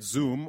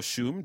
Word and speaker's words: Xoom: Turns out that Xoom, or Shoom, depending on the pronunciation you prefer, a digital money transfer company they recently acquired, plans to expand into Africa.